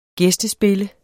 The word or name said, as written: gæstespille